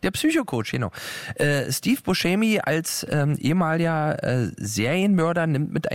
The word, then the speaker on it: Buscemi: Schrecklicher Titel, ein Film mit Steve Buscemi. Ach, ehrlich, so wird ausgesprochen. Wir waren uns gerade unsicher, aber viele sagen ja Buscemi. Und das ist natürlich völlig falsch. – Oder Buscemi.